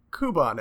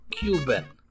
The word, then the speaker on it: Kuban: Kuban energy. – Cuban energy. It's K-U-B-A-N. Cuban.